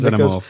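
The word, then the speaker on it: Xenomorph: I'm sorry, I'm laughing Zenimorph.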